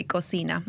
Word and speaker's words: cocina: So cucina is the Italian pronunciation. In Spanish, you will say cocina, and it means kitchen in both languages.